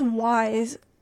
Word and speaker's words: WISE: And just making sure, it's pronounced WISE in short, right? – Yes. Yes, it is. You have it perfectly.